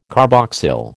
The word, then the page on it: carboxyl: American English